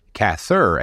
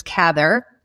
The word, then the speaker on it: Cather: I'm taking a hard TH, Cather, and you're taking more of a, a, uh, a softer TH. Is that how his name is supposed to be pronounced?